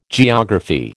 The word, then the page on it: geography: American English